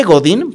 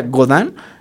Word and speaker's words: Godin: Se escribe Godín, pero me enseñó David Filio, un cantautor queretano, que se pronuncia Godin.